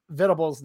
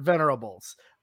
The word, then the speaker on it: Venables: Okay, I think a, a, very specific dig at potentially me says, "How do you pronounce Vit, Brent Venables' name?" And they spelled it Venerables. I accidentally have been pronouncing his last name Venerables on accident. It slips out.